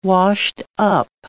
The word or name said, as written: washed-up